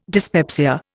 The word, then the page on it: dyspepsia: Indian